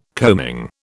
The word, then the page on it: combing: British English